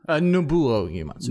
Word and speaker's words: Nobuo Uematsu: It is Nobuo Uematsu. Did I say that right? – Nobuo Uematsu.